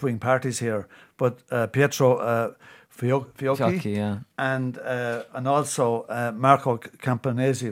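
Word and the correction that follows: Campomenosi: right? – Campomanosi. – Is that how you pronounce it? – Yeah.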